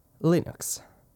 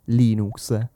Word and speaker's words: Linux: Il prossimo argomento è Linux e perdonatemi se l'ho detto con questa pronuncia orribile inglese, intendevo dire Linux, detto così in italiano.